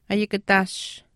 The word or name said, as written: ayikataash